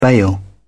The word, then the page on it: bail out: British English